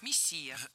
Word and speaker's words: мессия: Но и ударение в этом слове падает на второй слог «мессия».